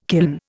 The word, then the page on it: kiln: Indian